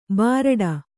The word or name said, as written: ಬಾರಡ